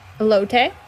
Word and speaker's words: elote: The number four ranked beignets, a fave of the Body by Beignet podcast, against number 13, and correct me if I'm saying this wrong, elote corn? – Elote.